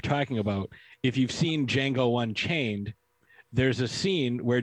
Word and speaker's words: Django: It's like Django. The D is silent.